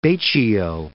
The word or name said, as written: Betio